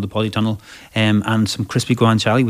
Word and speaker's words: guanciale: Guanciale.